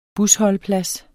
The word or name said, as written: busholdeplads